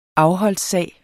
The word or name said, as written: afholdssag